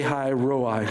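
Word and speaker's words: Lahai Roi: High Roi, that's how you say it.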